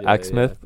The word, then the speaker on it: Exmouth: And you're from Exmouth, is it pronounced? – Exmouth. – Yeah, Exmouth.